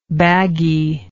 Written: baggie

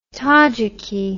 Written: tajiki

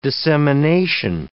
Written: dissemination

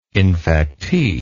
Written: infectee

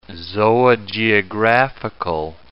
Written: zoogeographical

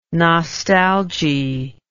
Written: nostalgy

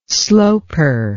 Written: sloper

